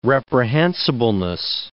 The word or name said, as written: reprehensibleness